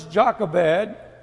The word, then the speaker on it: Jochebed: Her name, of course, is Jochebed. There's other ways to pronounce that, but that's the way I'm going to pronounce it.